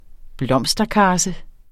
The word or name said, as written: blomsterkarse